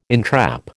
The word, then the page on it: entrap: American English